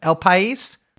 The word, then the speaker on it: El País: El País.